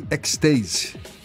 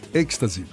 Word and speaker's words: ecstasy: Segundo a polícia, foram encontrados 450 comprimidos de MDMA e Ecstasy. Ecstasy, é isso mesmo? Êxtase. É, é, ecstasy, bem que eu senti que falei errado aqui. Ecstasy.